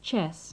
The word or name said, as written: chess